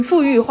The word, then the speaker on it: 馥郁: 它的富呢是富裕的富，这个富裕不是 rich，是呃左边一个香，右边一个重复的富。那满腹呢就跟满肚子是一个读音嘛，就是满腹。呃，一口饮尽馥郁花香果味，腹有诗书气自华。